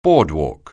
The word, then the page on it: boardwalk: anglais